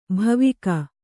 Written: ಭಾವುಕ

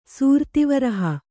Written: ಸೂರ್ತಿವರಹ

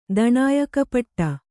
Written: ದಣಾಯಕಪಟ್ಟ

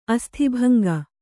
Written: ಅಸ್ಥಿಭಂಗ

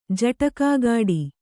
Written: ಜಟಕಾಗಾಡಿ

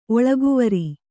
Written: ಒಳಗುವರಿ

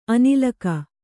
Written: ಅನಿಲಕ

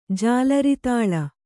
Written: ಜಾಲರಿತಾಳ

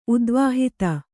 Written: ಉದ್ವಾಹಿತ

